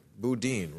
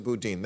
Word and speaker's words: Boudin: Boudin, right? Is that how you pronounce that name? – Yes. – Chesa Boudin.